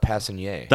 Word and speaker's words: Passenier: Or like, the French call it Passenier.